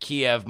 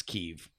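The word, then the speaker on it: Kyiv: Should I feel like a total butthead for pronouncing it Kiev my entire life until I watched the impeachment hearings and realized that it's Kiev? – Not at all.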